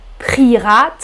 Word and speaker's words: prieras: Un verbe régulier, on prononce tu prieras très très bien, avec le E toujours.